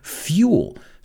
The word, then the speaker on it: fuel: Vamos a pronunciar esa palabra, fuel, you say. Se escribe igual, pero se pronuncia fuel.